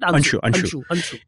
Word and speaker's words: Anso: Ansu. It's spelled A-N-S-O. – Yeah, Ansu. Ansu. – But it's pronounced Ansu.